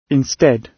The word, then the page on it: instead: British English